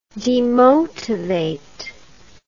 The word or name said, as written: demotivate